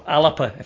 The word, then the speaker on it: Alba: So she's now, as I say, joined the ALBA party, or ALAPA, if we want to use its proper pronunciation.